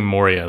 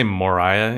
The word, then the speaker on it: Moria: So for me, I kept saying Moria the whole time. Did they say Moriah in the anime? – That's how they say it, I believe, in the Japanese.